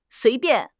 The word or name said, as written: sui2 bian4